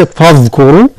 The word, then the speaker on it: فاذكروا: فإذا أفضتم، يعني نفخم كان الضاد، أفضتم هكذا من عرفات، كان الراك كذلك، فاذكروا وليس فاذكروا، فاذكروا كان الراء هي المفخمة،